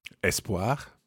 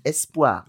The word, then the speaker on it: espoir: Espoir. – That's it. So it sounds more like an E grave, doesn't it? Espoir. – Espoir. – Okay, it's not espoir. It could be espoir as well, espoir.